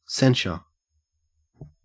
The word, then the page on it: censure: Australian English